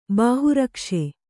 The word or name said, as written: ಬಾಹುರಕ್ಷೆ